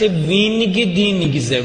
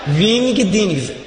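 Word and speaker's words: Wingdings: فونت وینگ دینگزه ویگولانجگیدی ها وینگ دینگیزه، تلفظ درستش دینگزه.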